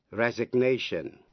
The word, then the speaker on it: resignation: Vậy ta biết động từ resign cũng theo lối phát âm như động từ sign, nghĩa là âm G cấm khi là động từ resign, nhưng khi thành danh từ thì G có phát âm resignation.